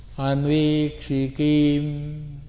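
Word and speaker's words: ānvīkṣikīm: No, you chant short. That's not the way. Anvikshikim, anvikshikim, that's the correct pronunciation.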